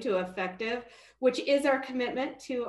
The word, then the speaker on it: Effectv: And I hope I'm saying this right because there's no I in there. So I want to make sure I'm saying it right. Effectv. It's formerly known as Comcast Spotlight. But how are you? – I am doing great today. Thank you so much for having me on. – I said it right too. Effectv? – You did.